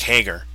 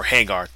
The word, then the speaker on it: Hager: And you've got the big muscle in Jake Hager, or Hagar, depending how you say it. Jericho says Hagar.